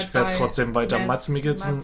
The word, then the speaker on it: Mads Mikkelsen: Übrigens, was ich jetzt herausgefunden habe letztens, kleiner Schock für mich, ich werde es wahrscheinlich trotzdem weiter sagen, Mads Mikkelsen wird eigentlich anders ausgesprochen. – Ach echt? – Ja, der wird, ist ja ein dänischer Name, ja. Wird eigentlich Mads Mikkelsen ausgesprochen. – Oh mein Gott. – So ein bisschen wie Mass Effect.